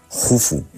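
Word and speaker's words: Khufu: Y eso lo aprovecha muy bien su hijo Keops, que por cierto, para cultura general, se escribe Keops, pero se pronuncia Jufu,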